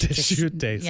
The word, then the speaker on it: Deschutes: I feel like the S is silent, so I think it's Deschutes. – Yes.